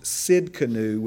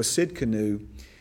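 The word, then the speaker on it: Tsidkenu: Jehovah pronounced Sidkenu with a T, Jehovah Sidkenu, means the Lord our righteousness.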